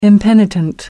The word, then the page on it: impenitent: American English